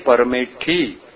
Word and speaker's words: परमेष्ठी: प्राकृत में परमेठी होगा, पंच परमेठी। एक छोटा बच्चा यदि इसका उच्चारण करेगा परमेष्ठी शब्द का तो संस्कृत उच्चारण उसको नहीं बनेगा, वो क्या बोलेगा, परमेठी बोलेगा, और इसलिए इसको प्राकृत कहा।